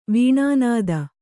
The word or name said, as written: ವೇಣುನಾದ